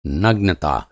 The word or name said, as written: नग्नता